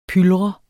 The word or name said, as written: pylret